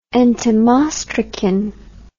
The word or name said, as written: entomostracan